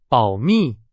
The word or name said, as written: 保密